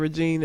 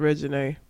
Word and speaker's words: Reginae: R-E-G-I-N-A-E. Nay on the end. So instead of Regine, it's Regine.